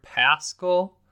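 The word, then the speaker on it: Paschall: Another guy is Eric Paschal. That's how I'm gonna pronounce it. I think that's what I've. Yeah, I'm pretty certain that's how you pronounce his name. I think I've said it incorrectly before.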